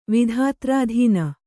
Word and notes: ವಿಧಾತ್ರಾಧೀನ: Kannada

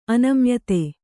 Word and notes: ಅನಮ್ಯತೆ: anamyate

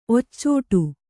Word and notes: ಒಚ್ಚೋಡಿ: occōḍi

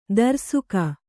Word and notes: ದರ್ಸುಕ: darsuka

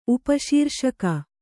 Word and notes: ಉಪಶೀರ್ಷಕ: Kannada

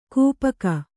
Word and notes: ಗೋಪಿಕೆ: gōpike

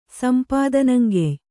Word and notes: ಸಂಪಾದನಂಗೆಯ್: Kannada

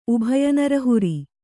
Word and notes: ಉಭಯನರಹುರಿ: Kannada

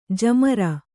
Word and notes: ಜಾಮಾರು: jāmāru

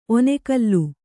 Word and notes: ಒನೆಕಲ್ಲು: onekallu

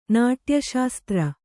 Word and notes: ನಾಟ್ಯಶಾಸ್ತ್ರ: nāṭya śastra

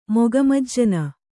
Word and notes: ಮುಖಮಜ್ಜನ: Kannada

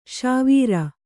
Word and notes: ಸೌವೀರ: Kannada

